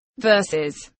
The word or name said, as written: verses